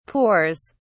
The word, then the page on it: pours: British English